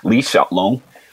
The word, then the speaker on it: Li Xiaolong: Li Xiaolong is how they pronounce it in Mandarin.